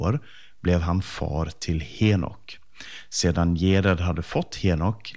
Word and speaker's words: Henok: Och Henok kan kallas ibland för Enok eller Hanok, beroende på vilket språk och vilken bibelöversättning man läser. Men jag har valt det uttalet som används i Folkbibelns 2015-översättning. Och Henok han nämns framförallt i Första Mosebok 5 i vers 18-24.